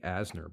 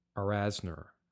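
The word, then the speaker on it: Arzner: I think I was pronouncing her name Dorothy Asner, but it's Dorothy Arasner. I hope I'm pronouncing that right.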